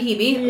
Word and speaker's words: Hebe: But okay, so they arrive at Heebie-Jeebies, which, A, thank you for giving me a firm ground to pronounce Hebe and know that it's Hebe. – Because Heebie-Jeebies. So I'm like, it's got to be Hebe.